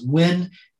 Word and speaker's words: Nguyen: And then I have the most common Vietnamese last name, which is Nguyen, it's spelled N as in Nancy, G U Y, like the word guy, E as in Eddie, N as in Nancy.